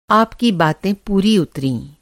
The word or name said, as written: पूरा